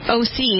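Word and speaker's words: O-C (όχι): I want to bring this conversation to the air because of the situation with the economy in Greece and the word oxy, O-X-I. – O-C is how they say it. – They say it that way, but it's spelled for English purposes. – Oxy. – O-X-I, which means no.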